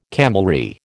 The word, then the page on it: camelry: American English